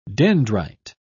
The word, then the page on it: dendrite: British English